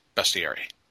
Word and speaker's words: bestiary: According to the online dictionary, it is in fact pronounced bestiary.